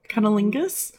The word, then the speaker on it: cunnilingus: Cunnilinguist. Mm. – God, sorry. Pussy. – I feel like I said that wrong. Cunnilingus?